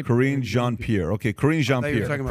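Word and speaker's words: Karine Jean-Pierre: Corinne Jean-Pierre. – Okay, Corinne Jean-Pierre.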